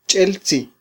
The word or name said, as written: ch'eltzi